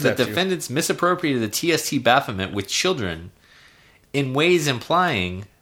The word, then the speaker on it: Baphomet: And basically, the temple, the Satanist temple, alleges that the defendants, which would be Netflix, misappropriated the TST Baphomet. And I'm saying that right, because I know... – Right, yes. Baphomet, you're down with the vernacular.